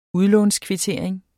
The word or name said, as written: udlånskvittering